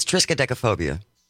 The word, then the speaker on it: triskaidekaphobia: Can you pronounce it? – It's triskaidekaphobia. – Good job.